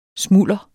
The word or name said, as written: SMULDERETS